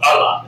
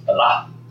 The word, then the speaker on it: Allah: That's just not Allah. We in English put the emphasis on the wrong vowel. They don't call it Allah. It's Allah. Allah.